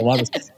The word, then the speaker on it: Lummis: did a rhymes with hummus and brought out hummus, which was an incredible side story about Cynthia Lummis.